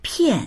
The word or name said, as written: pian4